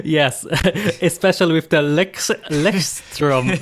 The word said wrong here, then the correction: Leuchtturm: I think I said Lechtrum or something. – It's Leuchtturm. – Oh, that sounds a lot better, doesn't it? Leuchtturm.